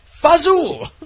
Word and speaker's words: fagioli: But all right, so how is the fagioli? How is that pronounced? – Pasta fagioli. – Fazul.